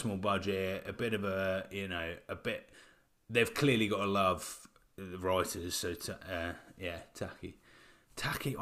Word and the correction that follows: Taika: Taika, that's it.